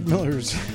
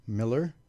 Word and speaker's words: Millar: Mark Miller's, or Mylar's, I never could get his name right. It's It's just Miller. – Miller? – Yeah, Miller.